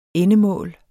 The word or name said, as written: endemål